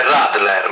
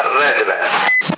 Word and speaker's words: Radler: Si chiama Redler, si legge Radler, ma si pronuncia Redler.